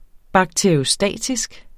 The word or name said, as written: Bakteriostatisk